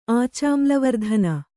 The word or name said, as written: ಆಚಾಮ್ಲವರ್ಧನ